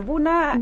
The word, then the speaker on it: Bunna: Bueno, Buna. – Buna, es, sí. – Digamos, se escribe B-U-N-N-A, para quienes quieran googlearlo o buscarlo en el suplemento Fintech.